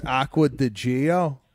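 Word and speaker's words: Acqua di Giò: I think the Aqua di Gio is a very safe play. I mean, it goes right in. – Okay. Watt, let me step in here. It's Aqua di Gio.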